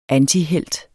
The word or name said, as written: antihelt